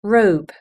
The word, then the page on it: robe: British English